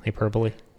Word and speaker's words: hyperbole: I can't pronounce it. You know that word. Why are you being weird? – Ball. – Have you never seen this word before? – Hyperbole. – Yeah. – Yeah. It's hyperbole. It is.